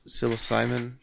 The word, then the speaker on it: psilocybin: It's like a psilocybin. Is that how you say it? – Yeah. The mushroom.